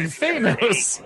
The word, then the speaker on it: Thanos: Thanos.